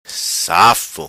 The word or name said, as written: Safo